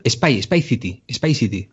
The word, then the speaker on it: Spy City: Bueno, pues se llama Spy City y es una serie que está protagonizada por Dominic Cooper. Este chico particularmente me gusta muchísimo porque ¿Spice? – Perdón, Spy City. – Ah, de espiar.